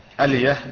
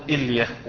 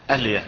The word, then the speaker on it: ألية: ويقال هنا يعني فائدة ألية بفتح الهمز ويعني مفتوحة الألف. قال ابن المنظور ولا تقل إلية ولا إلية فإنها خطأ، فبعض الناس ينطقها إلية، هذا خطأ، ألية بفتح الهمزة.